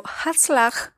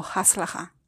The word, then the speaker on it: Hatzlacha: Así se deletrea esa palabra, pero la pronuncia como haslaja, algo así.